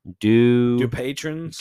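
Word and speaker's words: Dupuytren's: Looks like the pronunciation is do patrons. – That's what I thought. – De patrons.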